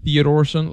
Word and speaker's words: Theodorsson: Theodorsson. – Unnar, U-N-N-A-R. – Yes. – And how do you spell that again, last name? – Theodorsson, like in Theodore. – Say it slow. – T-H-E-O-D-O-R. S-S-O-N.